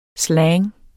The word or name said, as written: slang